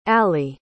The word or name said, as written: alli